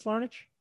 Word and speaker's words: Larnach: Larnach, Larnick, what do we got on that name? – I, I think it's a ch sound at the end. I think it's Larnach.